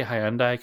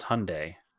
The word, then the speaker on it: Hyundai: I always say Hyundai because it's how it's spelled. That's how I remember how to spell it. – Hyundai. – But no, it's Hyundai. It's not Hyundai.